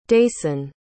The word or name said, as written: dayson